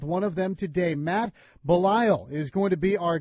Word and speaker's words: Belisle: As he told us before we began the interview, originally was pronounced Belisle, just like Fletch's ancestral homeland and Fletch lives, but Belisle is the customary pronunciation after 13 years in the big leagues, and you're going to enjoy getting to know him.